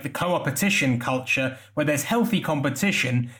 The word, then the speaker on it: co-opetition: And you talk about actually two opposing styles of working, competition versus co-opetition, if I'm pronouncing it. Yeah, no, that's right.